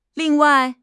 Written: ling4 wai4

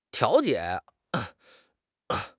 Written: 调解

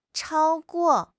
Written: chao1 guo4

